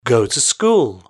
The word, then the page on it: go to school: British English